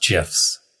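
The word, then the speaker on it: GIF: I'm sad to hear he died, but also he said that it's pronounced GIFs. He, the creator, said it's pronounced GIFs, which is crazy. – Like Jeff? – Like Jeff. Yeah, J. Like a J. Silent G, as he said.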